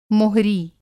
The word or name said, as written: मोहरी